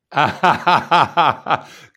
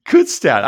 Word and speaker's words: Kutztown: Kutztown. – See how you're Pennsylvania. Kutztown.